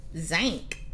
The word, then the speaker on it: zank: Z-A-N-K. – I thought Zank was spelled Z-I-N-K. – Actually, it's actually how you would pronounce, if you were from New Orleans, how you would pronounce a word that's spelled S-I-N-K.